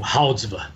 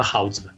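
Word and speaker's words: Goudzwaard: do Houdsva. Escreve com G, porque depois que eu descobri que o cidadão holandês escrevia com G e pronunciava Houdsva. Mas escreve Goldsvard, o nome dele.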